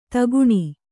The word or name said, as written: ಧೊಕ್ಕನೆ